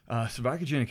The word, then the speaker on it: cervicogenic: I saw on your website that you do things to relieve servogenic headaches. – Yes. – Did I say that right? Servogenic? – Yeah. – What is a servogenic headache? – Cervicogenic headache is basically a headache that's induced by something that has to do with the neck.